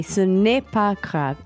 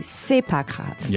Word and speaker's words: c'est pas grave: So, a lot of times in French, too, you don't say the ne. When it's written, you say, ce n'est pas grave. It is not grave. – But when you speak, kind of don't pronounce it. – Yeah, well, at least French people won't. You can, but when you'll probably hear people be, c'est pas grave.